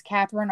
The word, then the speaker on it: Kathryn: It's Katherine Arnold.